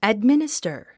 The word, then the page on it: administer: ædmínistə r; アドミニスタァ